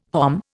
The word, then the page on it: hom: Indian